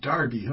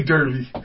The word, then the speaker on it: Derby: Thank you, Davey from Crime Off out of Nottingham, Derby, for Although I think they pronounce it Darby, huh? Like Darby Crack. – They do. – Yeah, they don't say Derby.